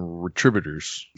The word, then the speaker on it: Retributors: But I like how you say it, how you call them Retributors.